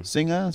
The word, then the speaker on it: Czinger: How do you pronounce it? – I, I was probably wrong. Zinger, zinger, zinger.